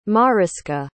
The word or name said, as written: maryska